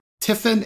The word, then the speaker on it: TIFIN: And so TIFIN is the name of the company.